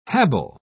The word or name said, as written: habile